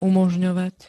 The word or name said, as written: umožňovať